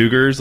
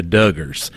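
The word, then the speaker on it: Duggar: No, listen, you're actually doing it correctly. I'm doing the southern spin on it with the Duggars. That's how we do it down here. You're doing the more actual, real pronunciation of it.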